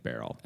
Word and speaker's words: Beryl: I haven't met enough people named Beryl. – Okay, go ahead. Sorry. – It's spelled like Cheryl, but with a B. B-E-R-Y-L.